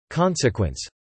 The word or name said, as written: consequence